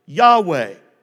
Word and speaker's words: Yahweh: Y-H-W-H. That's what it would be in English. So scholars tell us that it was probably pronounced something like Yahweh.